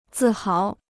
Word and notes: 自豪: zì háo

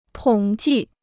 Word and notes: 统计: tǒng jì

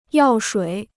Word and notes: 药水: yào shuǐ

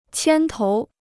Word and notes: 牵头: qiān tóu

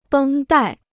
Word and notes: 绷带: bēng dài

